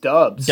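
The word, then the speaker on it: Doubs: Is it Dubs? – I think it's actually pronounced Dubs.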